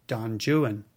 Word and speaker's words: Don Juan: And when we get to that poem, we'll talk about why it is, in fact, pronounced Don Juan.